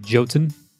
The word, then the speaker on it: Jotun: This one, the developers said it's called Jotun. – Jotun? – They said Jotun, but it's actually pronounced Jotun. It's like Jotunheim.